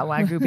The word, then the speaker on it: Wagyu: The dog's eating Wagyu. Is that how you say it? – Yeah, Wagyu beef.